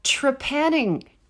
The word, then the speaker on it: trepanning: The skull pieces have circles drilled out from a trepanning device. Trepanning, am I saying that right? Yeah, tre- yeah.